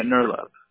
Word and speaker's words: Nirlep: No, it's N-I-R-L-E-P. – Oh. N-I-R-L-E-P. NERLAB. You pronounce it NERLAB, right? – Yeah, NERLAB. – NERLAB. Okay, gotcha. So N-I-R-L-E-P.